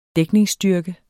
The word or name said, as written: dækningsstyrke